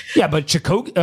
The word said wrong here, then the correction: Kipchoge: how do you say it? – Kipchoge?